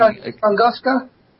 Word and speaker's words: Tunguska: of a spacecraft at the Tunska area. Have I got the name right? Because you've undoubtedly heard of it. And I was wondering... – Tunguska? In Russia? Siberia? – Yes. Right, yes, that's the one.